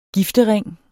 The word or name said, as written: giftering